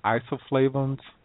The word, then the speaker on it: isoflavones: Isoflavones? Is that correct? – Yes. Those are the plant estrogens. And we find them in all soybeans, whether it's organic soybeans or commercial or GMO. There's always those isoflavones or phytoestrogens in there.